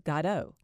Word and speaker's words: Godot: The play centers on two hobo-type characters, Estragon and Vladimir, who are waiting for someone called Godot. This pronunciation is proper, according to director James Ricks.